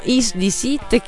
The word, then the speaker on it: Is This It: Dopo, però, prima ci ascoltiamo Is the Sit che leggo, sono Lead Strokes.